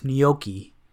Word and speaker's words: gnocchi: That's not how you pronounce it. I hope you're kidding. – How do you pronounce it? – It's gnocchi. – Oh, I thought it was Nochi. – No, it's a gnocchi.